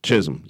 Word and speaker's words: Chisholm: In that case, of course, Shirley Chisholm. Is that how we pronounce her? – Chisholm, yes, Shirley Chisholm.